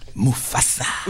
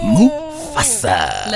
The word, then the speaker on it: Mufasa: You have to say it like that. Mufasa. Mufasa.